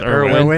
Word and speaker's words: Irwin: Yeah, Irwin. That's why he had to go. – Orwin, yeah. – It's spelled Orwin, but it's pronounced Irwin.